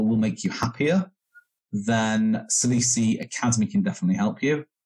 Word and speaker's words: Salisi: Well, Salisi is spelled S-A-L-I-S-I, Salisi.